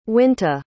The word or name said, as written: winta